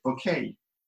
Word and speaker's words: Bucket: Well, this was a lady whose name was Hyacinth Bucket, but she would always pronounce it Bouquet, if you please.